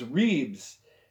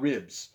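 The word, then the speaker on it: Reeb's: And the restaurant was Reeves. And Reeves. R-E-E-B apostrophe S. – And like costillas? – Close. But the pronunciation was Reeves, not ribs.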